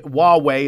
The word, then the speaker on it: Huawei: Huawei is how you say it,